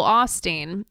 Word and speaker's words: Osteen: I'm with you about Joel Osteen. It's pronounced with a long O sound, FYI.